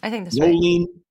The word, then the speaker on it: Rowling: I don't even know if I'm pronouncing her name right. Rowling. – I think that's right. – Rowling, maybe more like Rowling.